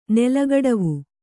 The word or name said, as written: ನೆಲಗಡವು